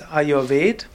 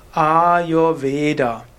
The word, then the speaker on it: Ayurveda: Aber eigentlich, wenn du Sanskrit korrekt aussprechen willst, auch wenn ich das selbst selten korrekt ausspreche, ist Ayurveda.